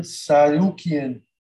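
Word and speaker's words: Tsarukyan: I'm going to let you pronounce that last name. Armand, and then Christos Giagos. – Armand Sarukian against Christos Giagos. – Want to butcher his name. You did that perfect.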